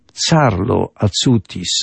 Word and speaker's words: Carlo Acutis: Carlo Acutis, czyta się, pisze się Carlo Acutis, bo to jest Włoch.